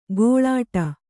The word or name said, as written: ಘೀಳಿಡು